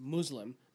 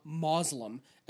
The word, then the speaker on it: Muslim: and it's it's and my understanding is it is pronounced Muslim, not Muslim, as I've heard so many people say. Uh, it's not M O S, it's M U.